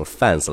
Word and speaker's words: fans: 那后面的字母 s 是一个很小的浊辅音，所以说整个词读下来就是 fans，fans。所以说以后千万不要读成 fans 了。